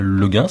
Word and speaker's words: Le Guin: Alors, normalement, elle préférait Le Guin, si j'ai bien tout compris, à la française, parce qu'elle a rencontré son mari à Paris.